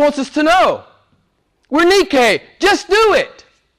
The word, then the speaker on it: Nike: Nike is how it's pronounced in the English world. It's Greek. Nike.